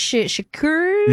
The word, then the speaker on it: Shakur: Shakur? – Yes, Shakur Stevenson.